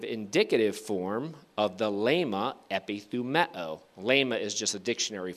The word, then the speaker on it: epithumeo: I'll spell that for you. E-P-I-T-H-U-M-E-O, epithumeo.